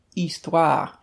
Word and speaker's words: histoire: And in French, H's at the beginning of words are not pronounced. So, like history, the word for history in French is histoire. And so you would put an an in front of it instead, because it sounds like a vowel at the beginning.